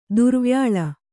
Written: ದುರ್ವ್ಯಾಳ

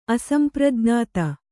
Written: ಅಸಂಪ್ರಜ್ಞಾತ

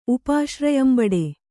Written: ಉಪಾಶ್ರಯಂಬಡೆ

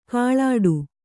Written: ಗೋಳಿಡು

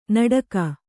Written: ನಡಗೆ

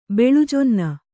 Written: ಬೆಳುಜೊನ್ನ

